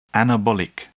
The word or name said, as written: anabolic